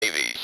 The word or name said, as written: navy's